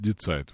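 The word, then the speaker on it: Die Zeit: Децайт, скорее всего, читается. Да, наверное, читается Децайт. Вы совершенно правы, Андрей.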